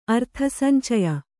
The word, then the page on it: ಅರ್ಥಸಂಚಯ: kannada